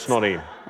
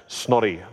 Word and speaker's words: Snorri: And it's just to be clear, it's not Snotty Snoots. – No, it's Snotty. Snotty. S-N-O-R-R-I, but you pronounce it Snotty, like that, Snotty Sturluson.